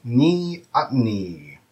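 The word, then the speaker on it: neoteny: And the word is ni-at-ni. Ni-at-ni. That is spelled N-E-O, like Neo, T-E-N-Y.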